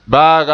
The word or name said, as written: baaga